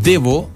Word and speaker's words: Devo: Anotamos Divo, pues, Claudia. Déjame anotar acá, Divo. Con B larga, ¿cierto? Divo. No, pues se escribe Devo. Devo, ¿cierto? Eso es de Devolution. De ahí viene el nombre de esa banda, ¿cierto?